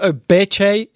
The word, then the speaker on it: Obeche: So it's not Obeche, it's Obeche.